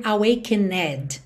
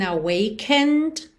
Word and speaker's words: awakened: Muito importante você reparar que esse E é final dessa palavra awakened não é pronunciado. Não é mesmo, então você não precisa nem se preocupar com ele. Você nunca vai dizer awakened, tá? ND direto. It's like I've been awakened.